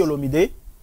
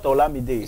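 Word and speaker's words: Olomidé: It's not Olamide. – It's Olomide. – It's Olomide. Kofi Olomide. – Okay. – Yeah. Don't pronounce it in English. Take it the way it is, man. – Like the way it is in the French.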